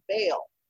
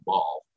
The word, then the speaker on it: Baal: And we actually just canned another beer in that series called Bale. And that's spelled B-A-A-L. – So immediately people are calling it Ball. – Right, they want to call it Ball.